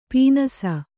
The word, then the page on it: ಪೀನಾಸಿ: Kannada